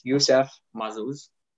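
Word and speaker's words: Youcef Mazouz: yes instagram is joe maz 05 okay g-o-e-m-a-z 05 this is for instagram for facebook it's yusef Mazouz uh, it's spelled Y O U C E F, mm-hmm.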